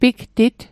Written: pigtit

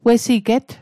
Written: wesiget

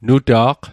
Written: nuta'q

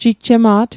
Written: jijjema't

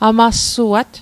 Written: amassuat

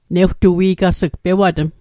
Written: newtui'gas'g

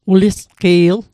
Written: ulisqi'l

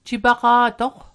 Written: jipaqa'toq

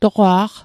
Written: toqwa'q

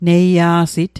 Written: neia'sit

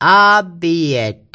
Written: a'piet

